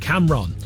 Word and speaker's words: Camron: Cameron Jones, and I've not said it wrong, it's spelt C-A-M-R-O-N, Cameron.